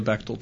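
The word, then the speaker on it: Bechdel: The Brechtel test? – Is that what it's called? – No, the Bechtel test. – Bechtel. – No, it's Breck. The R is silent.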